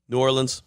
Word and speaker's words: New Orleans: How do you say it? – New Orleans. – No, so do I. Okay, good. Or if you like to say Nolans, I get it, whatever.